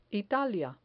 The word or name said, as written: italia